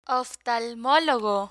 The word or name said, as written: oftalmologo